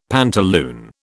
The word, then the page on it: pantaloon: British English